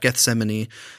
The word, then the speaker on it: Gethsemane: So they're at Gethsemane. I said it right that time.